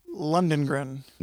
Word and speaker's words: Lundgren: Lundgren.